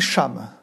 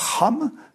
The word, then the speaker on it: Cham: Vous prononcez Cham. – Oui. – En hébreu, c'est Ham, et parfois, on prononce Cam. Notamment parce que c'est, il faut le rappeler, c'est le fils de Noé. Mais vous dites Cham, C-H-A-M.